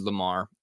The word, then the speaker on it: Lemarr: But my Instagram is just my name, David Lamar, L-E-M-A-R-R. Everyone spells my name wrong. It's spelled the white way, not the black way. L-E-M-A-R-R, not L-A-M-A-R.